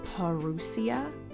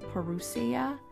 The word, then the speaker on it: parousia: Uh, the word Advent actually means coming in the Greek word, I think it was parousia, parousia. And uh, it's P A R O U S I A. I think I pronounced that correctly. I looked it up so I could get it right.